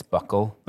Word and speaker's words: buccal: We all thought it was, and then Dan looked it up, and apparently it's buccal. But it's spelled B-U-C-C-A-L.